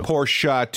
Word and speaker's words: Porsche: Porsche, two syllables, please.